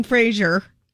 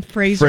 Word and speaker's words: Fraser: It's not Brendan Fraser. It rhymes with razor. Brendan Fraser.